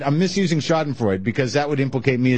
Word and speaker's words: Schadenfreude: Your feelings of your use of the German expression Schadenfreude, which someone had to point out to us, I think, is pronounced Schadenfreude.